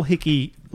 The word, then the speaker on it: Hikki: I think it's because I spell Hickey like my friend Nikki spells her name Nikki. So I spell it H-I-K-K-I, so I never actually thought it's how the nickname is supposed to be in Japan, because it's H-I-K-K-I.